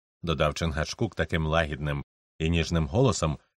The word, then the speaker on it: Чингачгук: «Чингачгук», – повільно вимовив вождь своє ім'я, наголошуючи на кожному складі. «Великий змій», мовою інгізів. – «Чингачгук», – повторила Гетті так само роздільно.